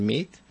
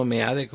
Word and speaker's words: Meade: El que lo sustituye en Hacienda, este Meade o Mit, como le gusta que le digan, Mit, no Meade como se escribe,